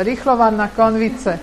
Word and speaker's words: rýchlovarná konvice: We like our rikle karvarnas. Is that how you say it here? Rikle konvice. – Konvice, rýchlovarná konvice. Yes.